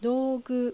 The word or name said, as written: Dougu